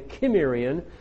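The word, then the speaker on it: Cimmerian: We have the rise of the Sumerian or the Kimmerian. You can use a hard C as well as a soft C there.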